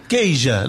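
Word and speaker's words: Queixa: Keija. Lo dije bien, ¿no? – Lo dijo bien.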